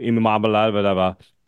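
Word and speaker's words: Iamaleava: People are talking about Nico Imamabalava at Tennessee. I believe I pronounced that correctly. – Yes, that sounds great.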